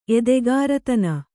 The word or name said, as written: ಎದೆಗಾರತನ